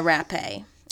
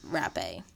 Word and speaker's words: Rappe: Alice Blake, Maude Delmont, and Virginia Rappe. And we believe her name is pronounced Rappe. – We looked it up. – We did, yeah, because I just thought it was Rap.